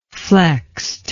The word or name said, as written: flexed